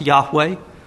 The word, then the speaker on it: Yahweh: And I'll say more later on about the names of God, in particular the four letters YHWH, the tetra four grammaton, four letters referring to YHWH, which was probably pronounced with the vowels A and E, Yahweh.